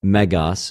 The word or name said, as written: μεγας